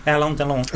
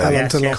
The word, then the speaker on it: Alain Delon: Yes, and is it Alain Delon? – Alain Delon, of course. – He's the you know, Alain Delon.